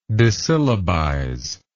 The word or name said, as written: dissyllabize